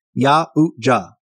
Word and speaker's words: Yautja: The Yautja, that's Yautja for pronunciation purposes, Yautja,